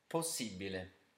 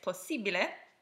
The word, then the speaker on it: possibile: Possibile. – So, if you guessed possibile, esatto, which you can probably guess means exactly.